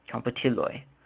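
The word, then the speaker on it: komputiloj: Plural nouns end in an OJ, and the J is pronounced as a Y. So computiloj, it's actually spelled K-O-N-P-U-T-I-L-O-J, computiloj. Ekranoj, that's a J at the end, so it looks like computiloj, but it's computiloj. J is pronounced as a Y in Esperanto.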